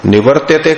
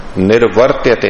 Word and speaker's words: निर्वर्त्यते: हाँ, निवर्त्यते करेंगे तो अलग हो जाएगा, निर्वर्त्यते ये उच्चारण में ध्यान रखना है, एक जैसे शब्द में प्राय त्रुटि होती रहती है इसमें।